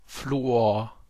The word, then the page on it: Fluor: /ˈfluːoːɐ̯/